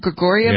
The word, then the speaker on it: Grigorieva: Grigorieva? – Yeah, that too.